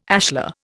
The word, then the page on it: ashlar: Indian